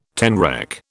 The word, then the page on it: tenrec: British English